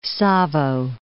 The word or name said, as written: Savo